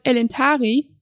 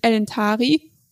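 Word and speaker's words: Elentári: Zum Beispiel, im Wort elentari enthält die vorletzte Silbe ta einen langen Vokal. Das sehen wir daran, dass da ein Akzent drüber ist, mit dem a. Und darum wird eben diese Silbe betont und es ist elentari und nicht zum Beispiel elentari.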